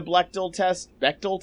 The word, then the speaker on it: Bechdel: I tried to be aware of the Bechdel test. What test? How do I pronounce Bechdel.